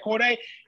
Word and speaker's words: Cordae: If you're a fan of Cordae, did I say it right, Juicy? – You did. – Isn't it Cordae? Yeah, Cordae.